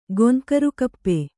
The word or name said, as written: ಗೊಂಕರುಕಪ್ಪೆ